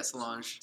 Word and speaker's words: Solange: Yeah, Solange.